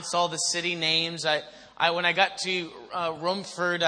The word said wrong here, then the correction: Romford: I said that wrong? Rom. – Not rum or rum. – Rom. Romford. See, I just got done saying I figured out how to pronounce the names.